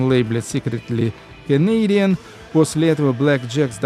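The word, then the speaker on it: BLK JKS: Black Jacks это произносится, а пишется BLKJTS.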